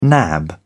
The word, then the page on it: nab: British English